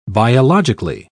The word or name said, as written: biologically